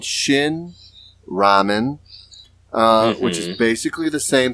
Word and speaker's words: ramen: Ramen is how you say it in Korean.